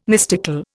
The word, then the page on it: mystical: Indian